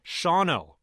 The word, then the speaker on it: Shawano: You look at it, it looks like a three-syllable word, Shawano. This stymied me for a long time. Joe, what happened? Where'd that syllable go? – It's just perfectly normal pattern of English. It's an unstressed syllable, and so you just lose it.